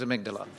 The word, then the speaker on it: amygdala: Is it actually pronounced the amygdala? – It is at St Thomas's, darling. I don't know. It depends which medical school you went to, but at Tommy's, it was amygdala.